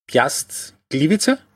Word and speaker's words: Piast Gliwice: Ähm, um das zu illustrieren, ähm, der Meister Piast Gliwice, habe ich das richtig mhm.